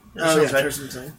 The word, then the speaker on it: Tarsem Singh: Tarsem Singh.